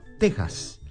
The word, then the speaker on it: Texas: Lo pronuncia con J, Texas.